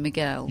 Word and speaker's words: Miguel: I'm pretty confident in Miguel.